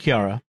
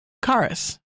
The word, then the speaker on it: Charis: It looks like Kiara. – Karis. – Karis. It's not spelled Karis. It's spelled C-H-A-R-I-A-S. – C-H-A-R-I-S. – I-S.